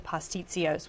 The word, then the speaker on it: pastitsios: And so it comes with, I hope I say this right, pastitsios.